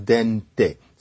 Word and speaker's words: dent: And so really what you see is tooth, and th has a certain relationship with t, and Italian has dente. So these things look like there's a relationship. French, it's pronounced dent today, but it's spelled D-E-N-T, which shows that earlier it was more like dente.